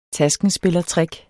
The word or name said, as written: taskenspillertrick